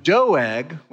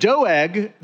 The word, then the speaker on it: Doeg: It says, to the choir master, a maskil of David, when Doeg, which is actually how you pronounce that name. I was trying to go with Doug and looked it up. But Doeg, the Edomite, came and told Saul, David has come to the house of Ahimelech.